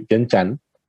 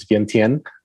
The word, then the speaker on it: Vientiane: Is it Vientiane? Is that how you say it? Vientiane? – Yeah, locally we would say Vientiane, but then internationally we're known as Vientiane. – Vientiane.